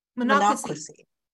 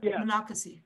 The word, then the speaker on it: Monocacy: There's a one, I'm going to say Casey. Have you, I don't know if I'm pronouncing it right. Have you been there? Casey? – Yeah. Casey? – In those big barns where, in the farms? Monocacy. – Mon- Monocacy. Yes.